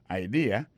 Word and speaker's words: idea: T, apostrophe S, good, G-O-O-D pour good, ça veut dire bon, bonne, idea, c'est I-D-E-A, ça veut dire idée, that's a good idea. Alors, accent vient tomber dans la deuxième syllabe là, one idea. Alors, moi, c'est idée, vous faites fait trois syllabes vraiment, I-D-A. On dit grand mot concernant trois syllabes là, mes amis.